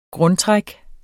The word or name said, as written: grundtræk